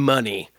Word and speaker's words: Money: Honestly, I mispronounced it. It's definitely Dr. Ken Money.